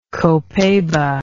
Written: copaiba

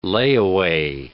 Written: layaway

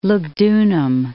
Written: Lugdunum

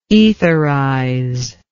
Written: etherizer

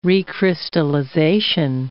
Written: recrystallization

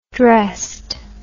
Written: dressed

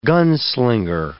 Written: gunslinger

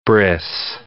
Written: bris

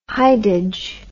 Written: hidage

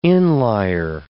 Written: inlier

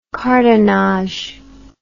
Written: cartonnage

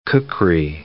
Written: kukri

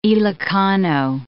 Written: Ilocano

